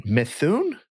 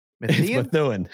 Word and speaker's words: Methuen: There's also M-E-T-H-E-U-N. – M-E-T-H-E-U-N. E-U-N. E-U-N. Methune? – Methuen.